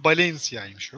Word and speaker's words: Valencia: Daha doğrusu Valencia'ymış o. – Valencia'ymış tamam. – Evet, İspanyolca'da V'ler, başa gelen V'ler B diye okunuyor.